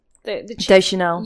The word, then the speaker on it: Deschanel: Deschanel.